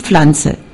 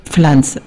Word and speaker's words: Pflanze: Dis Flanze. Pflanze.